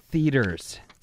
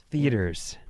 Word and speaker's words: theaters: It's theaters. – Oh, theaters. – Theaters. – Oh, that was really close. Say it again. – Theaters. – No. You gotta get that third syllable in there.